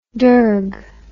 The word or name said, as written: dergue